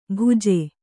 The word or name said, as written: ಭುಜೆ